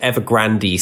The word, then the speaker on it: Evergrande: Historically, I went with Evergrande, but Evergrande seems to have much more of a dramatic flourish to it. So I think I'm going to put the accent on the E going forwards.